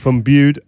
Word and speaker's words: Bude: I'm a funeral director from Bude. I hope I said that right. – Bude, yeah.